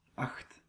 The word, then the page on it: heed: /hiːd/